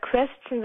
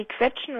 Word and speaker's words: question: Naja, anstatt Question sagt die Quetschen oder sowas.